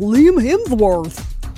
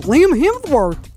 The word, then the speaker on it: Hemsworth: Liam Hemsworth. Why do you say his name like that? Whose name? Well, Liam Hemsworth. Oh, you mean Liam Hemsworth.